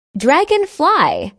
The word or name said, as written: dragonfly